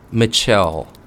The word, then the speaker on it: Mitchelle: So this strawberry, Mitchelle Blair, it is spelled Michelle, but with a T right after the I, Mitchelle.